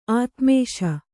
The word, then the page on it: ಆತ್ಮೇಶ: Kannada